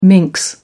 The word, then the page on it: minx: American English